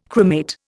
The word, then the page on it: cremate: Indian